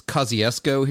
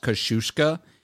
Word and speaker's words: Kosciuszko: But we have like a town called Kosciuszko. Actually, it's Kosciuszko here in Mississippi, but it should be pronounced Kosciuszko. It was named for a Polish-American general. And like we know how his name should be said, but Mississippians say Kosciuszko.